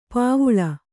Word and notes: ಪೂವಿಲ್: pūvil

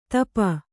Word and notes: ತಪ: tapa

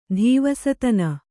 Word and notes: ಧೀವಸತನ: dhīvasatana

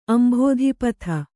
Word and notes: ಅಂಭೋಧಿಪಥ: ambhōdhipatha

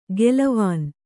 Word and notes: ಗೆಲವಾನ್: gelavān

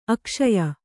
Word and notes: ಅಕ್ಷಯ: akṣaya

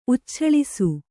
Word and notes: ಉಚ್ಛಳಿಸು: ucchaḷisu

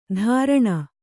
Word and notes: ಧಾರಣ: dhāraṇa

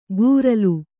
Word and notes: ಗೂರಲು: Kannada

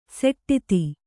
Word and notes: ಸೋಡ್ತಿ: kannada

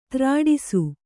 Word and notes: ತ್ರಾಡಿಸು: Kannada

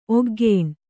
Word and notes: ಒಗ್ಗೇಣ್: kannada

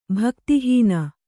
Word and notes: ಭಕ್ತಿಹೀನೆ: bhakti hīne

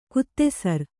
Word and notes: ಗೋಧಿಸರ: gōdhi sara

